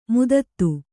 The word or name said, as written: ಮದದ್